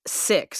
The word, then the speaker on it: Sikh: That's sick, spelled S-I-K-H. I remember mispronouncing sick as seek for a long time.